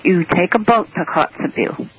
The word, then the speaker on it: Kotzebue: And evidently where Stephen is at in Kotzebue, that's how you say it? – It's Kotzebue indeed. There is no road from Fairbanks to Kotzebue.